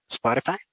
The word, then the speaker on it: Spotify: ¿Lo dije bien? Spotify, Spotify.